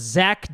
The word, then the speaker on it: Zak: This is your host, Zach Danger, spelled with a K.